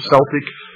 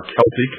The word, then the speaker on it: Celtic: Examples are ancient Mexican, Mayan, Inca, Aztec, Sumerian, Babylonian, Assyrian, Egyptian, Celtic, or Celtic as some pronounce it, Aryan, etc.